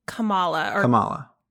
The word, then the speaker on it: Kamala: But if I had to pick one, I'd probably pick Kamala. Or is it Kamala? I don't know how you say her name. – Think it's Kamala.